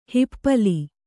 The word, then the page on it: ಹಿಪ್ಪಲಿ: hippali